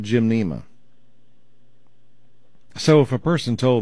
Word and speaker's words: Gymnema: G-Y-M, like I'm going to go to the gym, Nema, N-E-M-A, but it's just one word, Gymnema.